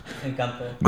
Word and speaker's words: Encanto: Encanto.